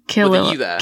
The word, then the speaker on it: Killua: it's like I th- I I say Killua because it's like Killa. – Ki- Killua.